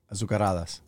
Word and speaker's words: Azucaradas: I think I said Azu. The Z is silent, right? It's no. – Azucaradas. – It's a Z. – How do you say it? – Azucaradas.